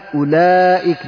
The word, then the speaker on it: أولئك: مثل أولئك، لا تقول أولئك، لا قل أولئك، لا تنطق الواو، مثل قالوا، الألف التي بعد واو لا، هذا للدلالة على أن هذه الواو واو جمعة.